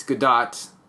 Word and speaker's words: Gadot: I looked it up and I said, how do you say Gal Gadot? And I saw a video of her introducing herself, and she introduces herself as Gal Gadot. That's the right way to say it.